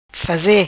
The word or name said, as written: faze